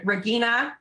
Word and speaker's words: Regina: Uh, uh, Regina. Uh, it's a hard G, but it's spelled like Regina mm.